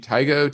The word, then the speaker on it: Tigo: Tigo, T-I-G-O. T-Y-G-O is the symbol. So it is probably pronounced Tigo.